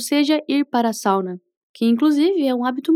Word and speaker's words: Sauna: Die Sauna, podemos presumir que é sauna. Porém, tem uma leve diferença na pronúncia do S. Vamos treinar só essas palavrinhas. Die Sauna. Die Sauna.